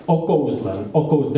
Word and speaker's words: okouzlen: And also the other aspect of this, is the declamation, because in Czech language we have the accents almost always on the first syllable, to say it simply. Okouzlen, there's the accent, but what accents into music as if the accent were on the second syllable of Kouzlen,